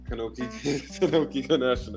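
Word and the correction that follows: Konosuke Takeshita: Am I saying his name right? No, I'm not. – Kanosuke Takeshita. I had to practice that.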